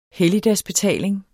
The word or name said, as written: helligdagsbetaling